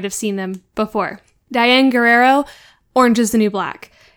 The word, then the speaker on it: Guerrero: I don't know how to say her last name, though. – Guerrero? – Guerrero. Okay, cool. It's Diane Guerrero, right? – Yeah.